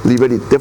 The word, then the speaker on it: Liberté: Liberté, fraternité, igualité, se dice, ¿verdad? – Sí.